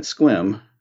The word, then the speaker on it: Sequim: I should explain that Sequim, pronounced like squid but with an M at the end, is too small to have a real theater.